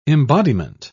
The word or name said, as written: embodiment